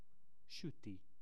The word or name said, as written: suti